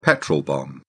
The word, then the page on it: petrol bomb: British English